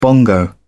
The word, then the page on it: bongo: British English